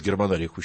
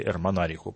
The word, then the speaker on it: Германарих: Но нравилось германариху считать, или эрмонариху по-разному произносится, считать, что у него в подчинении славяне есть.